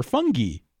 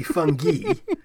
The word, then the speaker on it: fungi: It technically, in Latin, it would be, the I would be pronounced E, so it'd be fungi. – I like how the I is pronounced E, but the A-E is pronounced I. – That's my scream. And then other people go, well, yeah, but it's English, so you say fungi.